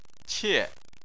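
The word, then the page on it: qie: tone 4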